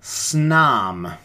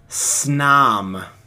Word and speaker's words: Snom: We've got Snom. – I'm sorry, what? – Snom.